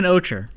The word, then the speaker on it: Ocher: It's Woman Ocher, you know, like the earth tone color.